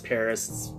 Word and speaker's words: PVRIS: It's a touring guitarist with the band, how do you say that, Paris? It's pronounced Paris. It's spelt P-V-R-I-S.